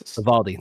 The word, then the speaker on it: Uvalde: Uvdale, Uvalde, Texas. – Uvalde. Thank you.